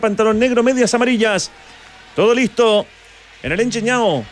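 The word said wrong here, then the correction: Engenhão: Está bien dicho, ¿no? no ¿Cómo se dice, Magda? – Engenhao. – ¿Cuánto? – Engenhao.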